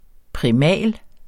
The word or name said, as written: primal